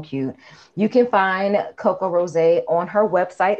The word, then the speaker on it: Rosé: It's T-H-E-C-O-C-O-R-O-S-E, but we're pronouncing it Rosé because, you know, put the stank on it.